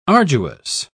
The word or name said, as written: arduous